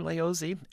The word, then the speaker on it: Laozi: It's interesting about his name, Laozi, L-A-O-Z-I. That is, by the way, the present-day Romanized pronunciation.